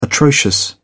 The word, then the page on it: atrocious: British English